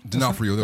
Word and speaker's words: D'Onofrio: Yeah, that's how it's spelled. You're pronouncing it right. Yeah, that's Vincent D'Onofrio.